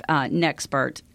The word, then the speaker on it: Nexpert: And then we also have Nexpert. And I did say that correctly. It's NEXPERT, Nexpert Business Coaching.